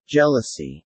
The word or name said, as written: jealousy